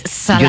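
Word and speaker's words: Szalay: Pues me traje un libro que se llama La Carne, que es de David Snars. Ahora pronunciaré bien su nombre. David Salai.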